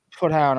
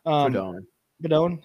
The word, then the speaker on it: Proudhon: It's Proudhon or whatever it is. – Proudhon, thank you.